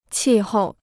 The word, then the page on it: 气候: qì hòu